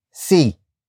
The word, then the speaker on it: si: Which is why the next word is see. See. See. See is spelled as S-I.